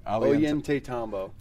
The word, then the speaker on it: Ollantaytambo: Ollantaytambo.